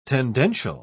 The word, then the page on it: tendential: British English